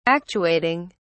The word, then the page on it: actuating: British English